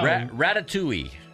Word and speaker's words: Ratatouille: Ratatouille.